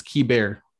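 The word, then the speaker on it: Keibert: Kiebert Ruiz or Key Bear. It's Key Bear.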